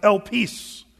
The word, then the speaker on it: elpis: The word hope, interestingly, here in our text, is a Greek word that's it's pronounced elpis.